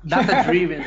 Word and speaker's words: Data Driven: É essa é a pronúncia? Data Driven. Sim, exatamente.